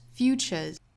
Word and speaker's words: future's: 那读起来的话，依然是要读成一个 z 的浊音，futures， the future's not futures。但是因为它是在两个单词中间，所以读的时候也不要读的过重，不是 futures，应该是很轻很快的 futures。